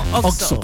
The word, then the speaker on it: Oxxo: Tú conoces muy bien qué es un Oxxo.